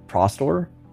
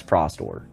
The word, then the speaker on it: Prostor: How did you pronounce the Russian guild again? – Prostor. – Okay. That's just interesting to me to see the kind of... – They have told us multiple times that's Prostor.